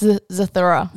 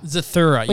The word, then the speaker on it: Zathura: Is it Zarutha? – You're very close. I'll give it to you. It's Zathura. – Zathura.